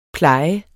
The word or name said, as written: Plejede